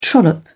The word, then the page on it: trollop: British English